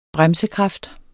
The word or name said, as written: bremses